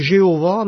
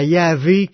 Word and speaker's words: Yahvé: Il y a des gens qui disent que c'est Jéhovah, mais c'est pas la vraie prononciation. Ça sera Yahvé qui est la vraie prononciation de ces quatre lettres hébreux-là.